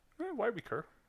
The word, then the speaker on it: YWECUR: Here's a random quick one from, I don't think your name is meant to be pronounced on Reddit, Y-W-E-C-U-R. – Wicker? Y-Weaker? – Yeah. – Y-Weaker.